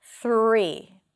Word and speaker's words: three: So to practice it, hold out the TH and hold out the R. It's going to help you. Three.